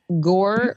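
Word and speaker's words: Gorr: You guys, Gore rhymes with Thor. – Gore does rhyme with Thor.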